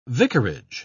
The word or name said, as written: vicarage